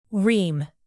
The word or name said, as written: ream